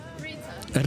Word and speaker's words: Rita: Rita.